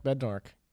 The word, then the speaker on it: Bednarik: Yeah, Chuck Bednarik.